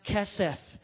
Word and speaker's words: hesed: That word in Hebrew is H-E-S-E-D, pronounced keseth.